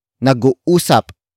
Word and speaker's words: nag-uusap: Word for word, it's nag-uusap pa kami. Nag-uusap pa kami. Nag-uusap pa kami. The first word is nag-uusap. Nag-uusap. Nag-uusap. Nag-uusap is spelled as N-A-G hyphen U-U-S. A. P.